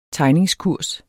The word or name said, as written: tegningskurs